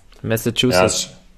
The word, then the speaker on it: Massachusetts: Wie wird das ausgesprochen? Das ist übelst schwer, Alter. – Massachusetts. – Ja, ist schwer für uns. – Massachusetts, ja.